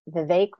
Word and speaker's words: Vivek: I learned to say it, Vivek rhymes with cake, Vivek Ramaswamy.